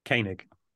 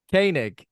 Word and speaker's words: Koenig: How do you say his surname? – Koenig. – Koenig.